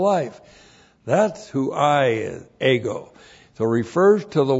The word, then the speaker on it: ego: And then the personal pronoun ego.